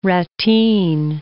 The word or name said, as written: ratteen